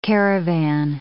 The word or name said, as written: caravan